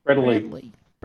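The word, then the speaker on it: bredli: What's the proper pronunciation of the scientific name of Morelia Bredels? What is the proper pronunciation? – Bredley. Bredley.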